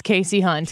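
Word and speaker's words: Kasie Hunt: So we all learned something today. It's Casey Hunt.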